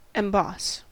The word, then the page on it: emboss: American English